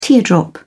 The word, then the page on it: teardrop: British English